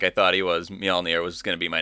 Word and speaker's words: Mjolnir: I'm going to take Mjolnir. – That Mew one. Mew. – Mew. I can't say it. Pronounce it like the J is a Y.